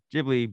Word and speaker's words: Ghibli: And the top comment, which has 9 upvotes, says, "In one of the special features of Nausicaa, they give an explanation about the word Ghibli being correctly pronounced with a hard G, like give.